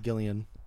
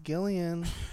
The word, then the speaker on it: Gillian: I think her name is actually pronounced Gillian. – Gillian Jacobs? – Yeah. Okay. I said Jillian Jacobs forever as well. Until I think somebody's like, it's pronounced Gillian.